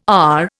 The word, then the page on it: AH: Indian